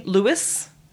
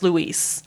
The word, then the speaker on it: Luis: as an American, we would say Louis. – yeah, probably. It is pronounced Luis.